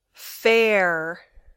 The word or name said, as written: fair